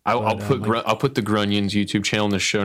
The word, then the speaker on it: Grunions: Like I like Grunions because it's, it rhymes with Funyuns.